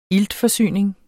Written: iltforsyning

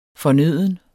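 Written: FORNØDENT

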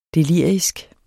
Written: delirisk

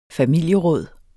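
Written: familieråd

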